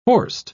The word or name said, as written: horst